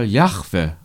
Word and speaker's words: Jahwe: Weil das Hebräische keine Vokale kennt, ist die Aussprache des Namens nicht ganz geklärt. Man nimmt heute die Aussprache Jahwe an und schreibt Jahwe.